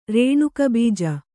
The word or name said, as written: ರೇಣುಕಬೀಜ